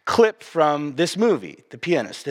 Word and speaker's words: Pianist: It's actually pronounced The Pianist.